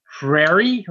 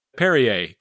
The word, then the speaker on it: Perrier: Prairie or Perrer? – Well, first of all, hold that thought. And Perrier? – Perrier, good.